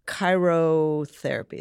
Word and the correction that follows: cryotherapy: Is that how you say it? Cryo. – Oh, cryotherapy, yeah.